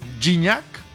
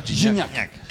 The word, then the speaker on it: Gignac: El paso perfecto de la selección mexicana ha sido tan arrollador que incluso el francés André-Pierre Gignac, así se pronuncia, ¿verdad? – Gignac. – Que no Gignac.